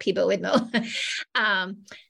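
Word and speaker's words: PiBoIdMo: It was picture book idea month, which everyone pronounced differently, but I called it Peebo Idmo.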